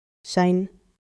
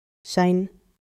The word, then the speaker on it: zijn: Zijn, zijn, zijn. Notice the long A sound from the IJ digraph. That's two letters working together to make one sound. And notice the soft N at the end.